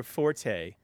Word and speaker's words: forte: Just to be clear, forte is the now accepted pronunciation.